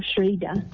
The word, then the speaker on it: Schroeder: Priscilla Schrader. – How do you pronounce the surname? – S-C-H-R-O-E-D-E-R, Schrader.